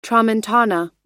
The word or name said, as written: tramontana